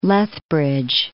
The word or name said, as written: Lethbridge